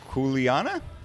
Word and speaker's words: Kuleana: It is Kuleana? – Perfect. – Was that perfect? – Yep, short okay. Vowel sounds.